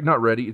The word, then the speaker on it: Reedy: Reedy. It's Reedy. – Reedy, yeah. – R-E-E. I think that's right.